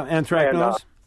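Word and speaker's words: anthracnose: Yes, I have a first-year market garden, and I got hit with anthrax nose. Mm-hmm. I believe that's how you say it. – Yeah, anthrax nose.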